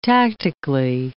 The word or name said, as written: tactically